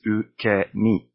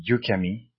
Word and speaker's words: ukemi: So it is ukemi. U, not you. So don't say yukemi. It is ukemi with a sharp U sound at the beginning.